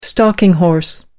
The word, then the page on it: stalking horse: American English